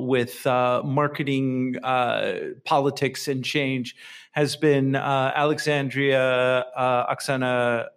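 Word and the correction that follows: Ocasio-Cortez: Ocasio Cortez.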